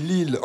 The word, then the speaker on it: Lisle: c'est Lille en Dordogne, ça s'écrit L-I-S-L-E, et ça se prononce Lille.